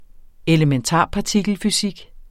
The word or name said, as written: elementarpartikelfysik